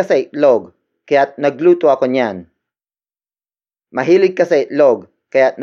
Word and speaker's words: niyan: The next pronoun is niyan. Niyan. Niyan. Niyan is spelled as N-I-Y-A-N.